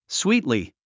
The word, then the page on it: sweetly: swíːtli; スウィートリィ